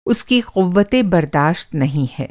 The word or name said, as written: क़ुव्वते-बर्दाश्त